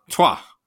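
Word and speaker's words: Troyes: He's on loan from, and now here we are, I don't know how to say this team name. – Troy? – Trois. Okay, well, that's you learn.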